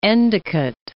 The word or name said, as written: Endicott